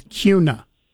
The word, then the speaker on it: Kuna: Since you're visiting, the proper way to pronounce it is Kuna. – I knew that, and for whatever reason, it came out Kuna. I know it's Kuna.